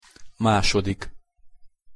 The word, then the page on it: masodik: Hungarian